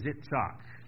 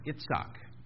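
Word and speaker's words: Yitzhak: In Hebrew, the name for Isaac is Itzhak. And with the emphasis on the last syllable, which is the SK sound, Itzhak.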